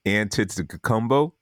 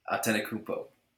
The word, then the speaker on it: Antetokounmpo: So number four is Giannis Antetokounmpo. Now, I should be able to pronounce that since I'm Nigerian, but it is Giannis. Yeah, say it again. – Antetokounmpo.